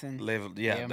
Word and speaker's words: Leviathan: Then I will go back and go to, in the Bible, there's mentions of all of this big animals, like the Leviathan, is how you say Leviathan.